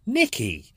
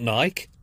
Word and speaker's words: Nikki: They are Pauline Honigsberg, Claire Emily, Nike Bagaji. I hope I got that right. Nike Bagaji. I've probably got Nike wrong. Like an idiot, I got the name wrong. It's actually Nikki, not Nike.